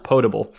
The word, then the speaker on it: potable: Well, you're both wrong, because it's not determined a waste corner, and it's pronounced potable. But Joyra, if you can drink it, then you put it in a pot, so it's pot.